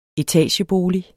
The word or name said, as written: etagebolig